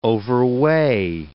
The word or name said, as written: overweigh